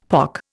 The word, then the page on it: pock: Indian